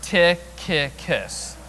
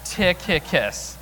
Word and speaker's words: Tychicus: Tychicus. Tychicus.